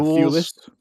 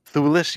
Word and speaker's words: Thewlis: David Thewlis. – Thewlis, yes.